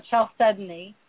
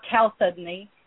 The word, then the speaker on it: chalcedony: And I pronounce it chalcedony and or chalcedony. It changes, it depends on the day.